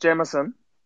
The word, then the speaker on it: Jamieson: I'm sorry to butt in, but is it pronounced Jamieson? – Yeah. Kyle Jamieson. – Is it? Yeah, I think it's Jamieson.